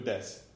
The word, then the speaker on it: desu: And desu means like, I guess that means it is... – Oh, right, because des ka means a question. – Right, des, and it's D-E-S-U, but you don't pronounce the U.